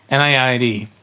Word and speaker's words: NIAID: NIAID.